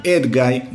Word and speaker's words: Edguy: Algunos pronuncian Edguy, bueno, yo le digo Edgy.